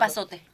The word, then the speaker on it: epazote: Epazote. – Epazote.